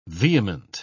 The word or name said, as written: vehement